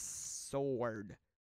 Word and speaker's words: sword: Hard W or silent W? 94% said it's sword.